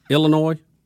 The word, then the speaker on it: Illinois: The guy, and I want to say he's at Illinois – University of Illinois, that Illinois, did I say that wrong? – You did it.